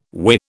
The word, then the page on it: whirr: British English